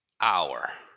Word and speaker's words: hour: Well, here, if you look at the phonetics, phonetically, it's hour. Okay. Typically, you say the H is like this house. You know, so here you say a house.